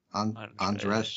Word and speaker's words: Andrzej: And Andrzej Zulawski? – Sure. Zulawski. – I'm whatever. Really just the first name. It's A-N-D-R-Z-E-J. So Andre.